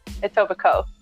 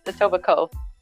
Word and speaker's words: Etobicoke: Is it Etobicoke or Etobicoke? – Etobicoke. – Oh, you don't pronounce the K-O? – You don't. Isn't there a K-E at the end? – Yeah, the last four letters are Coke, but it's Etobicoke.